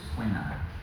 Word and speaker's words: poena: Thomas talks about one suffering he calls poena. P-O-E-N-A. Can everyone say poena? Poena. I think I'm pronouncing it right.